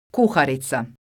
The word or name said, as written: kuharica